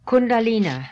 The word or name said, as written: Kundalina